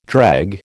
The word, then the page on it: dreg: American English